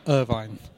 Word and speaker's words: Irvine: Welcome to one of the exclusive interviews with myself, the Sheriff Gary Stevens, and my very special guest at this time, Sam Irvine. – No, hang on a sec, mate. It's Irvin. It's a silent E, not a magic E.